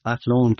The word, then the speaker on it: Athlone: founded by Aidan Bond and some colleagues in Athlone, Ireland in 2002. Is that, am I pronouncing the town right? – That's right, yes. Athlone, County Westmead, Ireland.